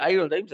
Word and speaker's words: Iron Dames: Las chicas del Iron Dames, o del, sí, del Iron Dames, así se pronuncia, eh, ganaron.